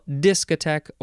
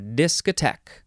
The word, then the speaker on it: discotheque: You can emphasize the first syllable or the last syllable. Discotheque or discotheque noun from 1954,